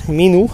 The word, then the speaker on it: Minu: Una es ah, Minu 2 Timer, Minu, tal como se escribe, M-I-N-U, eh, y el número 2.